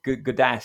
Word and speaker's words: Gawdat: Gaudet. So Gaudet, right. – G-A-W-D-A-T or something like that.